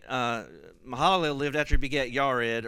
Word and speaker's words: Jared: Mahalalel lived 65 years, and he begat, English says Jared, it should be pronounced Yared. Yared. But I'm going to humor everybody here and call him Jared.